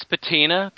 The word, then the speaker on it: patina: It's patina because it sounds like a name like Tina and not Tammy.